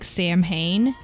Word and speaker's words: Samhain: It's spelled Samhain, like Sam Hain, but you pronounce it Samhain.